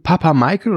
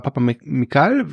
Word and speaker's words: Papamichael: Und der Kameramann ist dieser Grieche, Fidon Papamichael oder Papamichael, je nachdem, wie man es aussprechen möchte, der ja auch der Stammkameramann ist, unter anderem von lange Zeit von Alexander Payne und von James Mangold.